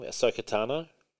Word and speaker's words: Ahsoka Tano: Ahsoka Tano, is that how you say her name? Ahsoka Tano? – Mm-hmm.